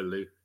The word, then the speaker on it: Lou: So Lou or Louis. – Okay. – But generally, Lou is fine.